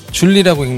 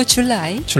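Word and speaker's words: July: 네, 자, 7월 1일 영어로는 줄리라고 읽나요? – 줄리 아니고 줄라이.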